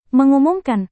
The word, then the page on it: mengumumkan: Indonesian